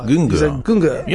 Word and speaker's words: Güngör: Das sag du mir bitte, wie man das mit den Umlauten richtig ausspricht. Du hast gesagt Güngör. Ja, das ist gut.